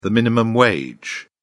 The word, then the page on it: minimum wage: British English